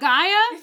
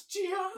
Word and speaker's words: Gaia: Gia. – Is it not Gaia? – It's Gia. – It's Gia. I only know because of the audiobook. Before, I was reading it as Gaia.